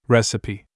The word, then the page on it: apron: English